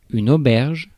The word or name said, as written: prison